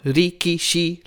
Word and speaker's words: rikishi: What I noticed that's super interesting about that sound is that it's almost like all three syllables have the same explosive expression. Like there's no, rikishi, like all on the same tone. Is that right? – Oh, so in terms of the intonation, I'm not sure, but they all do have the same vowel sound.